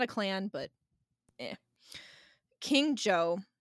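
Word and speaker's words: Zhou: I found out that you pronounce his name Joe.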